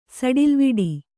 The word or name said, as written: ಸಡಿಲ್ವಿಡಿ